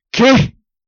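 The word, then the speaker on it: Ce: ¿Qué? ¿Qué? Que se pronuncia qué, pero se escribe C-E.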